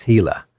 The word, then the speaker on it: Gila: The pronunciation is not obvious from the spelling, which is G-I-L-A. – Right, yeah, it's Gila.